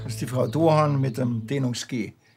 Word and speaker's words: Doğan: Doğan. Das G, das spricht man nicht mit. Das ist ein türkisches Dehnungs-G.